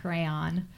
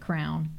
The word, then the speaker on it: crayon: I was a pink crayon, which yes. – is funny. Everybody makes fun of me because I always pronounce it crown. Crayon. I was a pink crayon.